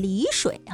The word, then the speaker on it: 丽水: 要说到这个，我们刚才说介绍的是浙江丽水啊，丽水虽然它是美丽的丽字，但是呢它是多音字啊，在地名上是要念丽水啊。